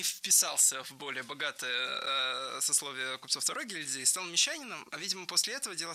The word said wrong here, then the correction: мещанин: Стал не мещанином, а мещанином. Мещанин, ударяем третий слог. Хотя во множественном числе мещане ударение падает на второй слог.